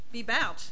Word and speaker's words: Bebout: The first testimony will come from Woody Baboot. Did I pronounce that right? – Bebout. – Got it wrong. Former Skagit Harley-Davidson worker, IAM District 160. – My name is Woody Bebout.